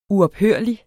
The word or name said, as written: uophørlig